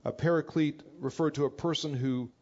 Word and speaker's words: paraclete: The term itself is a term parakletos, or sometimes pronounced in the English paraclete,